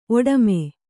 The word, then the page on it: ಒಡಮು: oḍamu